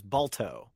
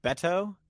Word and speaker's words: Beto: I thought it was Balto. I think his name is Beto. – Beto. – Beto. Beto. It's a kind of a Hispanic pronunciation. – It's a nickname for Roberto.